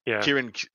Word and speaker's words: Ciaran: Ciaran Carroll. He's the Kieran Carroll. – It's Tolkien. Yeah, you got to go with that hard C.